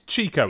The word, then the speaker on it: Chico: We have uh, I've always pronounced it Chikio. Same, I don't know. I think well because it's Chico.